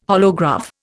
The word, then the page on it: holograph: Indian